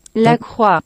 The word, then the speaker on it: La Croix: The brand posted Monday, La, you know, we won't say it, is pronounced La Croix.